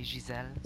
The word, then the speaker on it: Gisèle: See, it's hard to say in English because it's really a French name. Like, you have to say Giselle, so it's like...